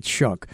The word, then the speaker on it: Shough: Again, I look at this word, I look at his name and how it's spelled. It's totally different from how it's pronounced. So it's kind of confusing. I mean, everything in my mind wants me to say it one way, but it's Shuck.